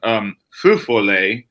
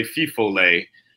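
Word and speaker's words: fifolet: And so, like, some people pronounce this one Fufole, which is probably more the correct French version. But again, just like Rougarou and Rougarou, it's we say Fifole.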